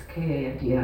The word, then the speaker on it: khanti: There's lots of H's in there too, after many of those double consonants. Mm-hmm. You don't pronounce any of that, but it's Khanti, as it were, but with an H.